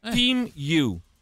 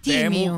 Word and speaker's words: Temu: Si pronuncia Team You. – Team You. – Team You. – Team You. – Con la I. Team You. Quindi non dite più Temu, che fate le ride se dite Temu.